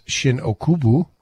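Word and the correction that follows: Shin-Ōkubo: Okubo. – Uh, Shinokubo.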